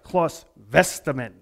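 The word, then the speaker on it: Claus Westermann: So now I can even say it right, Claus Westerman, right? There's no W's in the German language.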